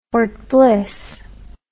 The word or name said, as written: Fort Bliss